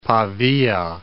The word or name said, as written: Pavia